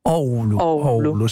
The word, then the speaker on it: Oulu: Guarda, questo è un mistero ancora, comunque dovrebbe essere Oulu. – Oulu. Si leggono tutte le lettere.